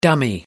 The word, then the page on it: dummy: American English